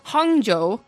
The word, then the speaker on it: Hangzhou: Hangzhou, Guangzhou, Chengdu. – Nice. – Yes. – Nice. – Yeah. So those three are the new Chinese teams. Obviously, the Hangzhou Spark are just killing it as far as, like, advertising, marketing with their logo and their colors and everything.